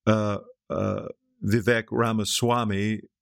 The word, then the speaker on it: Vivek Ramaswamy: So I wonder if that maybe Trump's agent Vivek Ramaswamy, which is the correct pronunciation, will take this matter up in the next debate.